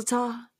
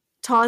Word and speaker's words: Tau: I was a founding chapter member of the Florida State Gamma Lambda chapter of Sigma Delta Tau. Tau is in law, not Tau is in cow.